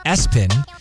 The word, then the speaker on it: ESPN: And I feel bad if this is anybody's first name, but ABCDE, which is actually pronounced Absudi, Lester, the name was ESPN, pronounced Espen, Ursula, like the Little Mermaid, Marvin, I don't know, still sounds marvelous to me, Bertha, Nevaeh, Archibald, Mulva, and the name Todd.